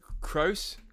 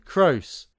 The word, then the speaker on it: Croce: Crocky. How would you say that? – Croce? – Croce. Don't mess with Jim.